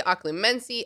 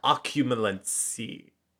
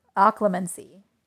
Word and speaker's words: occlumency: Occumulency. I don't know. The L's before the U.